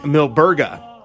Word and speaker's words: Milburga: It's Saint Milburga.